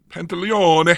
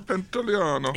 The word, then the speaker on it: Pantoliano: Stars this pretty much the same cast, Will Smith, Martin Lawrence, Joe Pantoliano. Wait. – Pantaleone. – Pantaleone. Yeah, if you say it with an Italian accent, it's easier to pronounce. Pantoliano. – Pantaleone.